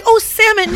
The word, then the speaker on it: salmon: I was like, salmon. No. the L is silent.